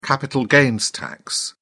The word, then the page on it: capital gains tax: British English